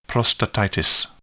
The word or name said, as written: prostatitis